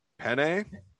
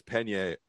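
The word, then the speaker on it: Peña: But on terms of talking shit, I love that Juliana, I'm going to say Penne. – Pena.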